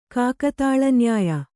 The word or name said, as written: ಕಾಕತಾಲನ್ಯಾಯ